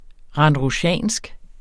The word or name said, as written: RANDRUSIANSKE